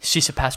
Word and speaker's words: Tsitsipas: Like, Tsitsipas took me 2 years to actually figure out how to pronounce his last name. – Silent T.